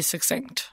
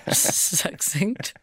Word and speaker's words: succinct: Just be succinct. – Be succinct. How did you say that word? – Succinct. – Succinct. – Succinct. Yes, more lines, less power.